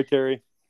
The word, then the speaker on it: Kerry: In regards to the long fancy name, in Cree, it means dark haired, which is what Kerry means. Okay. So it's Irish, actually, Kerry, spelled like a boy.